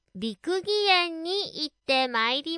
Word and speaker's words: 六義園: 漢数字の六に義、よしとも読みますね。義理のとか、そういう義にそのと書いて六義園と読むんですけれども、この六義園はですね、和歌の中に読まれる風景とかを再現しているらしいんですよ。で、もともとね、大名だった柳沢義康さん、川越藩主が作ったらしいです。